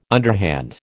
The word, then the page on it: underhand: American English